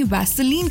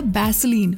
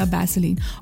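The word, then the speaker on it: Vaseline: Korea में beauty creators मिंडे अली जो है वो अपनी वैसलीन कलेक्शन दिखा रही थी, लेकिन प्रोनाउंसिएशन की वजह से वैसलीन सुनाई दिया बैसलीन, और इंटरनेट ने कहा कि बस अब तो बैसलीन ही है।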